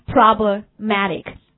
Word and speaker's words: problematic: 好，我们看它的那个衍生词语 problematic，注意它的那个 stress， OK, it's on the third syllable， OK, problematic，有问题的，对不对？